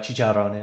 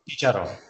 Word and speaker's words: chicharrón: We got empanadas and chicharrón. – Oh, you dog. – So for those of you that don't know what chicharrón is, it is... – Can you just say it one more time? – Chicharrón.